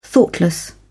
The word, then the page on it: thoughtless: British English